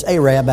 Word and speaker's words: Arab: It's not pronounced Arab. It's Arab, Alabama. And I was supposed to speak there one Sunday morning, and I was late.